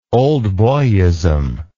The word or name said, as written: old boyism